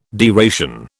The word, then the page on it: deration: British English